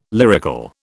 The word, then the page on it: lyrical: British English